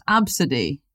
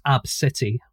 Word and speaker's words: Abcde: So, it's pronounced Absidy. – There's actually a couple of pronunciations, but that is one of them, yeah. – And sometimes it is pronounced Ab City, which is what I'm going to call my gym.